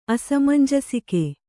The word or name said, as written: ಅಸಮಂಜಸಿಕೆ